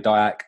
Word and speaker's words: Diack: But yeah, as you said, my name's David Diack.